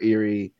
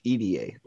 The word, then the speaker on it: Irie: So fun fact, if you want to, so Shigehiro, everybody always says Shigehiro Irie. It's actually pronounced EDA, like the letters EDA.